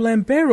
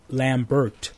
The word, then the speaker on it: Lambert: That's you know, well, he's a French actor, so every time that I heard his name being pronounced, it was always in the French pronunciation. – Maybe Lambert. – They did that to avoid, like, copyrights or something. – No, because I'm pretty sure that all the Americans who read his name read it phonetically out loud, Lambert.